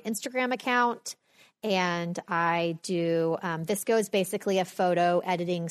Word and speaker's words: VSCO: So VSCO, which is spelled VSCO. It's pronounced VSCO. And yes, I looked it up because I'm like, I did not know how to pronounce it.